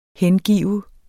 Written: HENGIVET

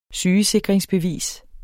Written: SYGESIKRINGSBEVISER

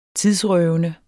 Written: tidsrøvende

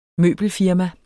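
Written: møbelfirma

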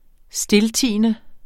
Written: stiltiende

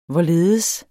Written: hvorledes

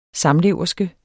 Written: SAMLEVERSKEN

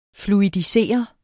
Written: fluidisere

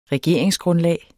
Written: regeringsgrundlag

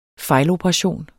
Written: fejloperation

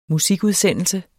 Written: musikudsendelse

